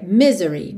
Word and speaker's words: misery: E o misery, acontece uma coisa muito parecida lá com pillow, que a gente viu. Não é misery, é misery, misery, me, e não me. Ok? Misery. Normalmente a sílaba tônica é mesmo a primeira, misery, misery, mas aqui na música a gente tem a life of misery.